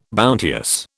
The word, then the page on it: bounteous: British English